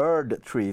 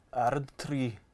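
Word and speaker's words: Erdtree: Shadow of the Erd Tree tror jag man uttalar det, för det sa de i trailern. Jag var lite osäker på hur det skulle uttalas. – Erd Tree. – Ja, det känns så tyskt i stavningen.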